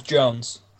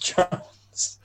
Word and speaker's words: Jonze: It is pronounced Jones. It's important. Oh, it's like GIF, isn't it? – Yeah, it's important to me that you know that despite the weird-ass spelling, it's pronounced Jones.